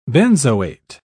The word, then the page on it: benzoate: British English